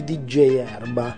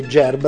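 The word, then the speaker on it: Djerba: Ad esempio, vorrei conoscere le offerte per il viaggio musicale in Tunisia di DJ Erba. Fai Gerba, si scrive DJ Erba, questo qua pensava fosse un DJ.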